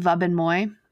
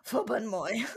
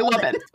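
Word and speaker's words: Wubben-Moy: To, I think you say her name Vuben Moy because she's German. – Vuben Moy. – Yeah. But it's spelled Wubben. And I really want to say Wubben.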